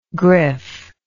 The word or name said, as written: griff